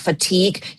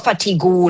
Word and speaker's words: fatigue: fatigue อย่าไปอ่านว่า fatigue นะ fatigue